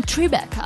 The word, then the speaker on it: Tribeca: Ma secondo te si dice Tribeca o Tribeca? – Secondo me bisogna dirlo con l'accento Tribeca.